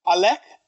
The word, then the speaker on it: Alech: The first one, I have to spell it because it's Tunisian. Alek, A-L-E-C-H, by the band Carthago, which is a portmanteau of Carthage and Agogo.